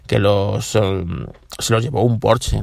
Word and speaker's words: Porsche: que se dice Porsche, no se dice Porsche, Porsche es como dicen, se pronuncia en inglés, pero realmente en alemán se pronuncia como en español, Porsche, y se dice Porsche.